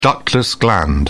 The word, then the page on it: ductless gland: British English